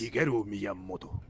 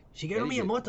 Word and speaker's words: Shigeru Miyamoto: Shigeru Miyamoto. – Shigeru Miyamoto.